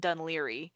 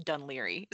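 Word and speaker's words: Dun Laoghaire: So it's pronounced Dunleary, but it's spelled D-U-N space. And this is where the Irish language makes no sense. L-A-O-G-H-A-I-R-E. And it's pronounced Dunleary.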